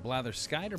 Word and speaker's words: blatherskite: All right, first word is blatherskite. Blatherskite. – Is it blatherskite or blatherskit? It looks like it's phonetically. Blatherskite. That's a long I.